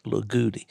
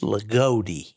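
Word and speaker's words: Loogootee: It's L-O-O-G-O-O-T-E-E, which sounds like Lagoudi. It's Lagoudi. – Lagoudi.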